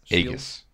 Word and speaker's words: Aegis: how do you pronounce that, you think? Aegis? Aegas? – Aegis. Is what Aegis. I always said.